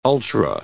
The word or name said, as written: ultra